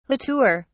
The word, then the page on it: la tour: British English